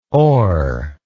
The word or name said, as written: oer